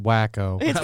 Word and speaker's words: Waco: We just started Waco. – It's pronounced Wacko.